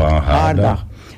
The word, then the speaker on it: hangaardag: Hoe spreek je dat uit? Hangaar? – Ja, open hangaardag.